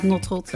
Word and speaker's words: Notrot: Ja, ik heb een hele moeilijke achternaam, dus daarom gebruik ik hem niet echt als social media naam, maar inderdaad Notrot.